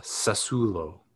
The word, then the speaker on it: Sassuolo: I'm gonna say Sassuolo. Sassuolo. – I think you said that one right. Even though everyone would say Sassuolo, I think he told us once, yeah, it's a different pronunciation.